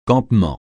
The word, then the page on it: campement: francais